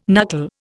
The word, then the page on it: knuckle: Indian